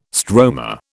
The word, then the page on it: stroma: British English